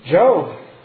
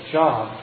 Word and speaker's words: Job: Job. Although his name looks like we should pronounce it Job, say Job.